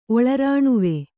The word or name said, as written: ಒಳರಾಣುವೆ